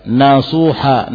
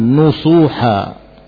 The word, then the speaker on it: nasuha: Mayoritas membaca ayat ini dengan nasuha, nun yang di fathah. Ada sebagian qiraah membacanya dengan dhammah. Al-Hasan Al-Basri dan Abu Bakar meriwayatkan dari Asim membaca taubatan nusuha. Nah, mayoritas membaca dengan fathah. Taubatan nasuha.